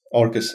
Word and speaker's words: Orkes: You work on Orcus. That is the correct pronunciation, right? – Yes. Orcus is our company.